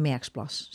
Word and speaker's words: Merksplas: En de donkerrode is van Zonderijge en Merksplas. Zeg ik dat goed? – Ja, Merksplas, Zwartgoor en Zonderijge.